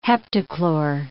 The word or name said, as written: heptachlor